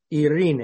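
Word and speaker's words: Irene (εἰρήνη): It's pronounced Irene. At least that's how I pronounce it. In Romania, it's Irina. And it's the Greek word for peace. Irene. Or we would say Irene.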